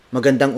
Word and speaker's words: magandang: It's Magandang umaga.